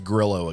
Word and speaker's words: Grillo: Emiliano Griot, 30-1, finished T33. – It's Grillo again, by the way. – Grillo. That's what I said.